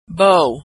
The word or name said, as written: bow